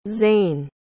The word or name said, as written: zayn